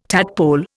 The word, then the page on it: tadpole: Indian